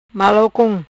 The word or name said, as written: Malau con